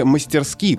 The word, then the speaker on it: мастерски: Э, хочу заступиться за наречие «мастерски», прозвучавшее в последнем подкасте с ударением на первом слоге. Словарь Аванесова, как и большинство других, однозначно определяет постановку ударения на последнем слоге. Прагматика в данном случае не является оправданием, так как ударение на «а» сильно режет слух.